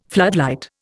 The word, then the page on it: floodlight: Indian